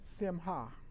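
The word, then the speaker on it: simcha: And in Hebrew, joy is simha, S-I-M-C-H. A-H. In Hebrew, you don't pronounce the C.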